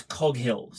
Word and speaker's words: Coghill: I'm going to pronounce her surname as Coghill. So Nikki Coghill. – I don't know if it's Coghill or Cohill. But her character is Annie. – Every other person who I've seen with that surname pronounces it as Coghill.